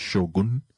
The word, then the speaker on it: Shogun: You would have read it that way, but the actual pronunciation is Shogun.